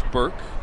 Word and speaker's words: Bourque: James Burke spells his name B-O-U-R-Q-U-E, but pronounced Burke.